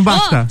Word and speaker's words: BAFTA: É isso mesmo, ah, BAFTA.